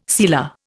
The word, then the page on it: sealer: Indian